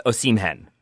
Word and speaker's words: Osimhen: I have now gone with Osimhen. And I think that's what it is, Osimhen. I had it flipped the H before the M.